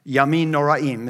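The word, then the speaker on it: Yamin Noraim (ימים נוראים): You see, Rosh Hashanah begins a 10-day period known in Jewish circles as the high holidays, Yamin Noraim is how you say it in Hebrew, And it's a time of penitence and it's a time of prayer.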